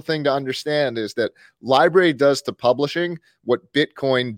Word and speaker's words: LBRY: Library. Yeah. – Library or library? – Just library. Just library. Like library, like a place with books.